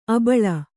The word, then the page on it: ಆಭೀಲ: ābhīla